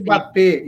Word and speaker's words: Mbappé: É Mbappé, é é Mbappé, isso aí.